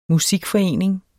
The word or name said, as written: musikforening